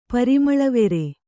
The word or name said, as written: ಪರಿಮಳವೆರೆ